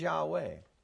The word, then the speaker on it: Yahweh: The early biblical pronunciation appears to be Yahweh with a labial labial. I learned this at Fuller. They drew a little diagram when I was taking Greek and Hebrew. And, you use the lips, to pronounce the W.